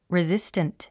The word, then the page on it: resistant: rizíst ə nt; リズィスタント